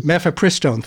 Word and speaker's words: Mifepristone: Mifepristone, I'm not sure how to pronounce it. I haven't used it. – Yeah, Mifepristone.